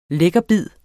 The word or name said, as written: lækkerbid